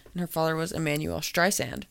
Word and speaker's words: Streisand: How it's Streisand. That's how you say it.